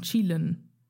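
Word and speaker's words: Qilin: Chilin. Also es wird Chillen? geschrieben mit Q, aber wird Chilin ausgesprochen.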